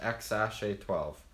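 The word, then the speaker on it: X Æ A-12: He says the X is pronounced X, and the AE is pronounced Ash, and the A12 is pronounced A12, so that the kid's name is pronounced XASH A12.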